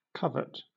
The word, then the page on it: covert: British English